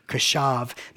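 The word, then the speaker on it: kashav (קשב): The Hebrew word there for paid attention is a Hebrew word kashav, which I probably just totally, brutalized by trying to say, but that's how it's spelled and that's how you pronounce it, is kashav.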